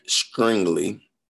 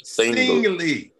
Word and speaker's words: Stingley: I'm gonna go Derek Stringley. Did I say that right? – Sting. Stingley.